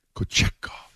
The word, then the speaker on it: Kochetkov: Kochetkov. – Because we said it enough yesterday. – I said it so. I bought 4 different ways. Kochetkov.